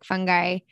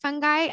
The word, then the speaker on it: fungi: I've always called it fungi until that film where they say fungi. Is that a tomato, tomato kind of thing? Like, you can pronounce it either way. – You can pronounce it either way. Yeah. Like, a lot of people in different countries say it different ways.